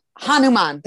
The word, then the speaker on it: Hanuman: Hanuman. That's it. I always say it wrong.